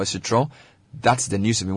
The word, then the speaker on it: Citroën: Well, beginning 4th July, all Peugeot and Citroën, is that how it's pronounced? – Citroën. – owners can visit any Silver Star Auto Workshop in Accra. It's almost like French. You have to speak it with some...